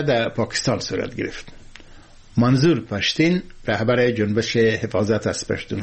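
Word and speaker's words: Pashteen: از شبه که در برخی مناطق خیبر پشتونخوا و همچنان در برخی بخشای افغانستان مثل خوست حرف واو به شکل یا معمولا تلفظ میشه. به همین دلیل مسعود که بعضی از رهبران تحریک طالبان پاکستان تخلص میکنن مسید تلفظ میشه و به همین ترتیب پشتون پشتین تلفظ میشه.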